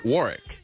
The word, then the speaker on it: Wzorek: That's spelled E-U-G-E-N-E. That's Eugene. And Warrick is W-Z-O-R-E-K.